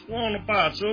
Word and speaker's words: Buonapazzo: It's buonapazzo. It means crazy good. You're not saying it right. Buonapazzo.